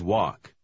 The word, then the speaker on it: walk: Помните, что буква «Л» в последнем слове немая. – We always walk.